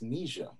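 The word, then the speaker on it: Nija: I actually found out it's pronounced Naija.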